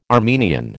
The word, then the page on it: Armenian: American English